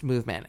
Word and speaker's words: MVMT: It's spelled MVMT, like the band, but it's pronounced movement.